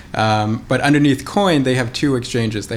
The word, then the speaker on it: Quoine: And so people know it's Coin. It's spelled Q-U-O-I-N-E.